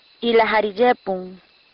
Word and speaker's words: Ilajariyepun: Dejemos que una muchacha de por aquí, nos lo diga con mejor pronunciación. – Está lloviendo. En malecu se dice. Ilajariyepun. Ilajariyepun.